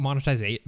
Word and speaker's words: monetization: Where's the extra syllable that I'm missing, dude? That's crazy. – Monetization. – Is there... Oh, I think I'm trying to add another syllable, but there's not one.